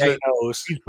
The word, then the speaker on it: Thanos: Thanos. Gano. It rhymes with Ganos. Thanos rhymes with Ganos. – And you he wears Thanos.